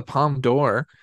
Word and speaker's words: Palme d'Or: The Palme d'Or? – Yeah, the Palme d'Or.